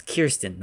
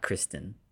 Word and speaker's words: Kirsten: It's Kirsten, not Kristen.